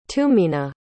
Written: tumenah

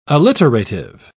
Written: alliterative

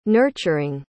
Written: nurturing

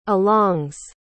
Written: alongs

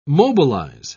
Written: mobilize

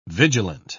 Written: vigilant

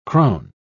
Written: crone

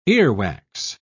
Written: earwax

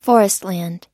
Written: forestland

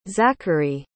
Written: xakery

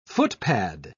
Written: footpad

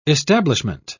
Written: establishment

